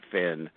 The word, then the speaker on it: Fionn: It's spelled F-I-O-N-N, but you pronounce it Finn.